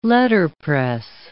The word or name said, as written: letterpress